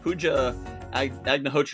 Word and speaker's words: Pooja Agnihotri: So we have a guest joining us today, Pooja Agnihotri.